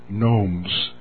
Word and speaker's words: gnomes: In fact, the Lord gave me the word gnomes. The G is silent. G-N-O-M-E-S.